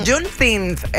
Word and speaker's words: Juneteenth: Juneteenth. La hija mía me estaba ayer corrigiendo cómo pronunciar esto. Juneteenth, allá lo saben.